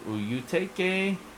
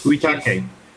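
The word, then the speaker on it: Uyetake: Colorist Charlie Kirkoff, letterer Neil Uyutake, and editors Uyutake. – Uyutake, thank you. I didn't say it right.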